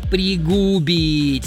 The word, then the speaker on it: пригубить: Я думаю, пригубить. – Нет. Данное слово образовано от существительного «губа», представляете? А не от глагола «бить», как вы подумали. Поэтому правильно говорить «пригубить», то есть прикоснуться губами.